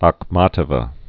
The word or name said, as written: Akhmatova